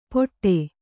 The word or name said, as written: ಪೊಟ್ಟೆ